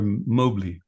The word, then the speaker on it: Mobley: You pronounce her Mobley. So yes, she pronounced it Mobley.